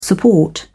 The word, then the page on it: support: British English